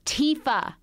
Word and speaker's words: Tifa: Why can't you have a simple name like Typha? – It's Tifa.